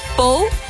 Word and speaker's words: Pau: Van Pau naar Gavarnie-Gèdre. Zeg ik het goed, Jan? Gavarnie-Gèdre? – Ja, het is tussen Pau. – Pau.